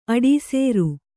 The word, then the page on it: ಅಡೀಸೇರು: aḍīsēru